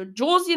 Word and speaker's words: Josie: Also, Josie in Klammern wird übrigens Englisch ausgesprochen.